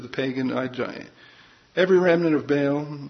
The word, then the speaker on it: Baal: And Baal is pronounced Baal in Hebrew.